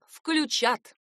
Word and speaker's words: включат: 8 непослушных паучат учат ударение «включат». Паучата делают правильно. Этот вариант ударения надо обязательно запомнить, потому что верен именно он.